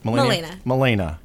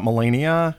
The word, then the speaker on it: Milena: Let's start with Milena. Am I saying that right? – Milena. – Not Melania. – Elena with an N.